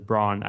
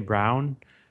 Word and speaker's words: Braun: You pronounce it as Braun. I used to say Brown for some reason because it has the U.